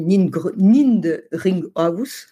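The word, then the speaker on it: Niedringhaus: Alors, je ne sais pas très bien comment on dit, Nindringhaus. – Comme ça se prononce. – Oui, ça se prononce comme ça s'écrit.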